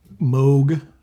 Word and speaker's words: Moog: I, it's it's actually pronounced Moog, but I have not in the years that I've known that it's pronounced Moog. I've not been able to train myself to say Moog instead of Moog.